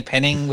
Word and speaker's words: Penningh: So, Jamie Penning with a H on the end, because the H is silent and everyone forgets it.